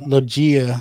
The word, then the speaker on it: Loggia: Robert Loggia.